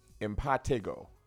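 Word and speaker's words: impetigo: By the way, we pronounce it wrong. It's pronounced impatigo.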